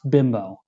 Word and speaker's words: Bimbo: It's spelled B-I-M-B-O. I promise you, it's not pronounced bimbo. It's bimbo.